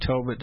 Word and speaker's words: Tobit: Well, I've always remembered how to pronounce it by saying Tobit does not rhyme with Hobbit.